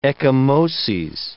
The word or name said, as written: ecchymoses